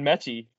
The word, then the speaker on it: Metchie: I'm actually upset you asked me that way, because I was going to say John Metchie.